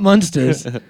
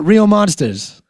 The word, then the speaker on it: Real Monsters: You need to give respect monsters. – To the show. No, not Munsters. – No, Monsters. – I'm saying, Real Monsters.